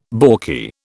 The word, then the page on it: balky: British English